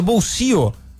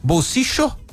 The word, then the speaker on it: bolsillo: O caçap em espanhol, que se pronuncia bolsio. E tem as três pronúncias do duplo L lá na Argentina. – Isso. – Né? Bolsicho, como se fosse um CH, né?